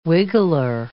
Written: wiggler

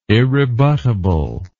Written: irrebuttable

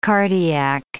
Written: cardiac

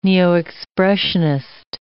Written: neo-Expressionist

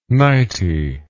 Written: nighty

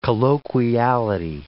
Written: colloquiality

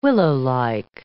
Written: willowlike

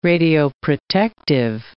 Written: radioprotective

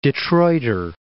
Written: Detroiter

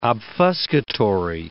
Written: obfuscatory

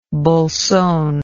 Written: bolson

